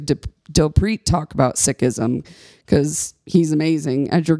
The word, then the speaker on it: Sikhism: So it is Sikhism, and when you say it plural, it's six, like the number.